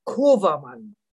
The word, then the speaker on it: Kovermann: Rainer schreibt man nämlich in meinem Fall mit R-E-I-N-E-R. – N-I-R. – Am Ende, ja. Und dann geht es, der Familienname ist Kovermann, fängt mit K an, O-V-E-R-Mann.